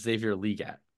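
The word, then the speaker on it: Xavier Legette: Question one, do you guys care that it's actually pronounced Xavier Leggett? – No.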